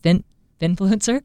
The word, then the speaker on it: finfluencer: Is it finfluencer? Mm-hmm. Am I saying it right? – Yeah.